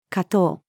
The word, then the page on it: 果糖: female